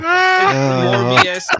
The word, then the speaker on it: Morbius: Morbius. Yeah, he mistyped it. Morbius. Yeah. And I said it wrong.